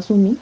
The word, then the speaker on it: Soomei: su nombre se escribe S-O-O-M-E-I. Mi mamá consideró que el nombre era muy complicado y, o sea, se pronunciaba Sumi, entonces ella decidió ponerme la pronunciación del nombre, Sumi.